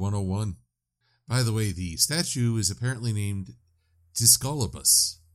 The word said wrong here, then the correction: Discobolus: I'm sorry, I mispronounced that. Discobolus.